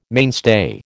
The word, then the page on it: mainstay: American English